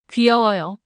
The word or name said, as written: 귀여워요